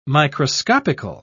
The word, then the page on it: microscopical: British English